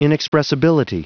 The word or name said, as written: inexpressibility